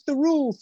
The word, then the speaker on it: Thirroul: I had a poster up on my bedroom wall of the Thirroul Train Museum, and I remember the moment when I finally could pronounce the TH in Thirroul, rushing into my mum and dad's bedroom and saying, "I can say Thirroul, Thirroul."